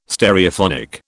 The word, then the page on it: Stereophonic: British English